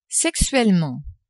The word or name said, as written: sexuellement